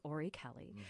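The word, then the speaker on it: Orry-Kelly: And that is spelled just like it sounds, Ori Kelly, who was another great designer, also known for really influencing fashion and using kind of high fashion within the context of films.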